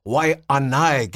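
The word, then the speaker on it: Waianae (Waiʻanae): Oh, no Waianae, bro. Waianae, get the Okina, brother.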